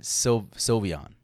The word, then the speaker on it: Sylveon: Mamoswine and Sylveon.